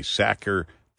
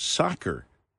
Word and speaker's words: Sacher: We are pronouncing it, I was pronouncing it incorrectly, Sacher Tort Day, because I S-A-C-H-E-R. She said it's actually pronounced Soccer Tort, which is should be easier to remember for me, but I leave it up to the women.